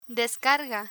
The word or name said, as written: descarga